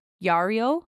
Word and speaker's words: Jarilo: And in many Slavic regions, I believe it's pronounced Yaro, J A R I L O, Yario, but the J is very much like a Y sound.